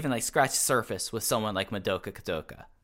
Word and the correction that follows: Kikuta: You know, Kikut. I got the name right when I was pronouncing it before. Madoka Kakuta. I think that he has something really interesting to watch.